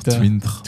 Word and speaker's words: Twint: Ähm, haben wir es A richtig ausgesprochen und B ähm, Twint, richtig Twint.